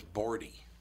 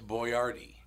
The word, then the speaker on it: Boyardee: They thought his name was Bordy. Chef Bordy. No, it's Boyardee.